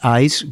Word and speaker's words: ICE: que son las redadas de los llamados ICE, por sus siglas en inglés, ICE, que se pronunciaría más o menos como hielo, que es el Servicio de Inmigración y Control de Aduanas de Estados Unidos.